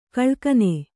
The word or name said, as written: ಘಲಕನೆ